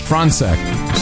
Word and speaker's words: Franchek: want to start off with uh, one of our uh, producers today, Lawrence Franchek. Franz, uh, how do you pronounce again? I want to get it. – Fronsec. Sorry, Lawrence Fronsec uh, congratulates himself.